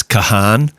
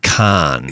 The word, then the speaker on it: Kahan: So I think we blew it and said it's Kahan. – Yeah. – But it's actually Khan.